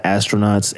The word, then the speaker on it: Astronavtz: So follow us at The Astronauts, or it's actually at Astronauts, ASTRONAVTZ. The V is a little fancy U.